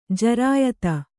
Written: ಜಿರಾಯತ

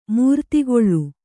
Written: ಮೂರ್ತಿಗೊಳ್

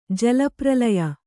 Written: ಜಲಪ್ರಲಯ

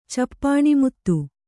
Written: ಚಪ್ಪಾಣಿಮುತ್ತು

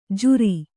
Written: ಝೂರಿ

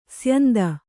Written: ಸ್ಯಂದ

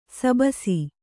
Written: ಶಾಭಾಸ್

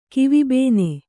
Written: ಕಿವಿಬೇನೆ

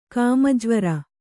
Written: ಕಾಮಜ್ವರ

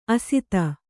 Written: ಅಸ್ತ

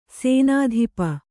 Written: ಸೇನಾಧಿಪ

